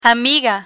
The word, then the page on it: amiga: spanish